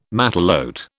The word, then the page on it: matelote: British English